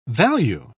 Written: value

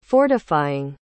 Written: fortifying

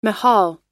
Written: mahal

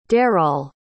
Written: dareall

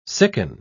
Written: sicken

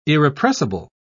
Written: irrepressible

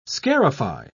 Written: scarify

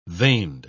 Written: veined

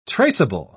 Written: traceable

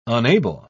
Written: unable

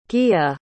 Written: Gaea

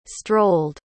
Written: strolld

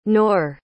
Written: knorr

